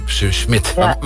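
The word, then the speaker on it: Smith: Toen zei Gijs, ja, het is Saskia Smit. Toen zei ik, nee, gek, het is gewoon Saskia Smit. – Het is zelfs in Nederlands uitgesproken, ja. – Smit. – Maar, wat doet die H dan? Die doet dus eigenlijk niks.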